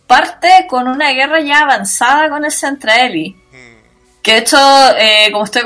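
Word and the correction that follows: Zentradi: No, mira, se dice, en la versión japonesa se dice Centradi, y en Robotech le dicen Centraedi, con una E.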